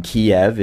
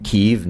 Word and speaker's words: Kyiv: You know, again, Kiev is the Russian pronunciation, Kyiv and Lviv,